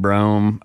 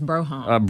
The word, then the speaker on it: Brougham: I'd love a mid-80s Fleetwood Brougham, but, and... – I'd want a two-door. – Yeah, so you got to say it right, too. It's Brougham.